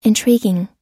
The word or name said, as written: intriguing